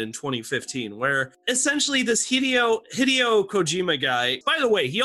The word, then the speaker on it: Hideo Kojima: Hey, and JP, say his name one more time. – Hideo Kojima. – Hideo Kojima. Thank you.